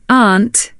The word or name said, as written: aunt